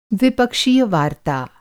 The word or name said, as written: द्विपक्षीय